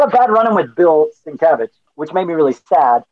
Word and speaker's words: Sienkiewicz: My mom dropped me off, and I had a rough meeting with a guy named Bill Sienkiewicz, or Sienkiewicz, as I used to call him. Sienkiewicz! I thought that's how you pronounced it.